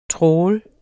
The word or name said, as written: trawl